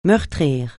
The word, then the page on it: meurtrir: francais